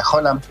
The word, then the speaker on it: Haaland: Después de Haaland, que no se pronuncia Haaland, dice que se pronuncia Holland.